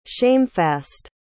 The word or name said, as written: shamefast